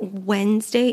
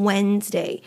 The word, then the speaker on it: Wednesday: And the way that Wednesday is spelled, it has a D in there, Wednesday. However, you're not going to hear it in the pronunciation. We never pronounce the D in Wednesday. So if you ever happen to pronounce it Wednesday, you are pronouncing it incorrectly and you want to avoid that.